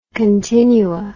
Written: continua

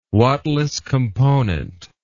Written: wattless component

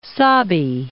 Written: Sabi

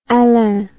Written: Alain

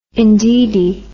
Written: indeedy

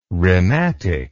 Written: rhematic

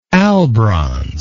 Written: albronze

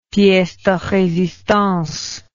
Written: piece de resistance

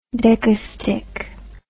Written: decastich

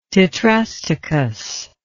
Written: tetrastichous

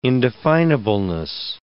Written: indefinableness

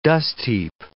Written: dustheap

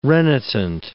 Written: renitent

